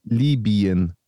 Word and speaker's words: Libyen: am besten spricht man Orte mit Y einfach so aus, wie sie im Griechischen auch heißen, nämlich I. – Ja, Libya. – Libyen.